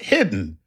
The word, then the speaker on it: Hidde: Chuck, Hitty is pronounced hidden without the N.